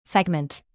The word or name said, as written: segment